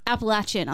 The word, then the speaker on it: Appalachian: Now you know how to pronounce it properly. – See, I think I got my pronunciation from American TV. Like, yeah, a lot of people get it wrong. Yeah. And like even and I was quietly pleased. I know we'll get to this in review, but they pronounce it correctly on their own podcast. Hopefully. – Well, you would hope that they would. – Yeah, but yeah, it's Appalachian.